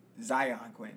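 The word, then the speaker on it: Xyon Quinn: Zion Quinn? – Zion Quinn. That's how you pronounce that? – Yeah.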